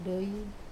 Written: doi

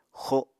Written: xo

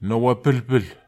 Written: nowapelpel

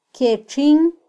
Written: kyetxin

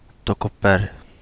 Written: Togo Bale

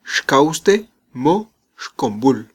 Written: xkawste mo

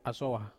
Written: asowa

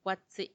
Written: guatsi

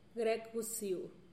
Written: grekgusiu

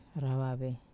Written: rawave

